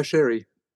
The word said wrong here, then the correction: Cherry: Oh, Ava Cherry. – Ava Cherry. Yeah, sorry for my pronunciation, you know.